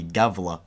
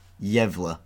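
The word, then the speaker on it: Gävle: I mean, I'm going to be sort of talking about a similar sort of topic from that part of the world, about the Gavla, or Yevla, as it's supposed to be pronounced, goat.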